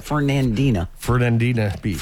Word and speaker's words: Fernandina Beach: One's in Bradenton, one in Winter Haven, one in Davenport, and one in Ferdinandum Beach. How do you say that? – Fernandina Beach.